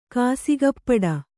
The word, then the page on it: ಕಾಸಿಗಪ್ಪಡ: kannada